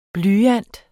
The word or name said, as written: blyant